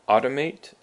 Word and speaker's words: automate: Then we have automate and automatic. Automate has the emphasis on the first syllable, and that's a verb that means 自动化.